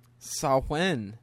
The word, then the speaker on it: Samhain: It's difficult when it's hot, but it's getting cooler outside, and Samhain is coming up. – Say what? – Samhain. All Hallows Eve. – That's what it's called? – Well, that's the Celtic pronunciation.